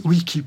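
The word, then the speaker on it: vici: Vicky. – Vicky. – Wiki, plus exactement.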